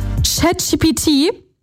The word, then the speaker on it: ChatGPT: Es ist statt ChatGPT ChatGBD.